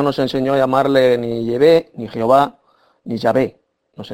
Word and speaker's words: Yahvé: Es un nombre propio, tiene un nombre propio que es Yahvé, esa es la auténtica pronunciación, pero un hijo siempre a su padre le llama papá, le llama padre, nunca le llama por su nombre propio.